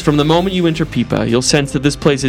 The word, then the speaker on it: Pipa: And I'm going with Pipa, even though there's some discussion about how to pronounce it. – Yeah, Pipa sounds right. – Pipa, P-I-P-A.